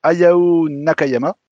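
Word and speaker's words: Hayao Nakayama: Bah oui, le président de Sega Entreprise, donc c'était, alors excusez-moi si je prononce mal, pardon, Ayao Nakayama, c'est bien prononcé ah, c'est, euh, ça passe.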